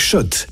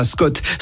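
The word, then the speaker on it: Schott: Jouer et gagner sur RMC avec Shot, la marque iconique new-yorkaise depuis 1913. Oui, Shot et pas Scott. Ça fait des années qu'on prononce mal la marque.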